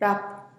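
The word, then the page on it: rap: Teotitlán del Valle Zapotec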